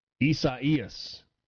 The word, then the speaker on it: Isaias: Somebody said that it's Caleb in Starkville says that this is pronounced Isaias. The Spanish and Portuguese pronunciation for Isaiah. I guess if I think back to my my Spanish classes from years ago, and that's probably correct pronunciation.